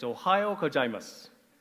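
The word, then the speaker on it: Ohayou gozaimasu (おはようございます): It's Ohayou gozaimasu.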